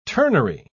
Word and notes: turnery: British English

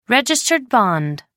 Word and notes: registered bond: American English

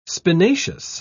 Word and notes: spinaceous: British English

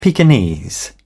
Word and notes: pekinese: British English

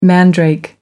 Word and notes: mandrake: American English

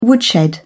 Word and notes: woodshed: British English

